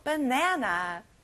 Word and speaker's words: banana: 여러분, 영어로는요. 에 소리 나요. banana.